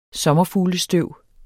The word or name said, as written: sommerfuglestøv